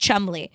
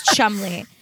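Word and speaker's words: Cholmondeley: I do know this is pronounced Chumley. It's spelled Chomondeley, but it's pronounced Chumley, which is so British.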